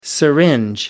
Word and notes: syringe: American English

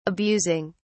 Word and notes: abusing: British English

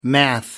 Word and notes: math: British English

